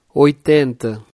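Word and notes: 80: Portuguese; Brazilian Portuguese

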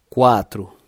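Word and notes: 4: Brazilian Portuguese

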